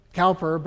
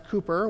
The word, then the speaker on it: Cowper: It looks Cowper, but it's pronounced Cooper.